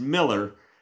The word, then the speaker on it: Mueller: But it's Bill Mueller, but it's Bill Mill. It's pronounced Miller.